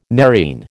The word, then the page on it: nerine: American English